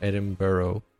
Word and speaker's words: Edinburgh: Um, people will tell you it's Edinburgh, not Edinburgh or Edinburgh, which I say a lot. Uh, Edinburgh. That's how they pronounce it.